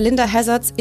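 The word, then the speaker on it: Linda Hazzard: Und als sie von Dr. Linda Hazards Institute of Natural Therapeutics, habe ich das richtig ausgesprochen? – Ja.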